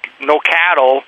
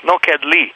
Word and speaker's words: Nokedli: But see, Nokedli is spelled N-O-K-E-D-L-I, and the Hungarian always pronounces the O as an O, or E as an A, and the I as an E. So it's always Nokedli.